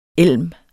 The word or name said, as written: ELM